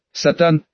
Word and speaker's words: Сатан: Сатан, с ударением на второе А.